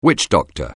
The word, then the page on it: witchdoctor: anglais